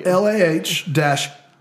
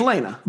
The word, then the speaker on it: Lana: And invites They just didn't have, the Facebook chat said that it's Lana. – How did they phonetically tell you? – LAH-NAH. – It's Lana. – Lana is what you said.